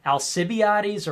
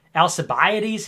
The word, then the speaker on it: Alcibiades: Somebody told me that it's actually pronounced Alcibiades, or what is it? Alcibiades, he said. That's how it's pronounced. Alcibiades, he says. That's the correct pronunciation, but force habit.